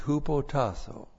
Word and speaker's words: hupotasso: Now in verse 7, the key word there is hupotasso. H-U-P-O-T-A-S-S long O. Hupotasso.